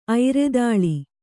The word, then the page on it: ಐರದಾಳಿ: Kannada